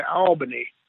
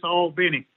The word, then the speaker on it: Albany: Out here in West Texas, we say Albany, but y'all don't pronounce it like that, do you? – No, it's Albany in this part of the world, just to make that distinction. It's spelled the same, just pronounce it different.